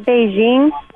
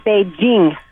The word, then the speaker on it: Beijing: And it's not Beijing. It's Beijing.